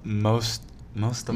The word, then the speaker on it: Mosta: Okay, how do you spell that? Mitsa. – Mosta. Mosta. – Oh, Mosta, Malta. Found it.